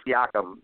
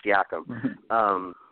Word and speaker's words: Siakam: Viacom, yeah. Viacom, however you pronounce his name. Siakam, yeah, that's it, Siakam.